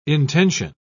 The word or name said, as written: intention